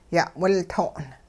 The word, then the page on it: ya'wilto'n: hupa